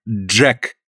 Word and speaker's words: Джек: Имя пишется и произносится на английский манер. Вот так. Джек.